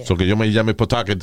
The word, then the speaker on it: Pawtucket: Uh, Aris Mercado, desde Pawtucket, Rhode Island. Am I pronouncing that right? Yes, you got it right. Pawtucket.